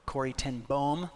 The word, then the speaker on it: Corrie ten Boom: Application: we must trust, in the words of Corrie ten Boom, or Corrie ten Boom, the double O in the Dutch is actually pronounced O.